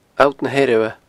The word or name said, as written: Allt na h-Eirbhe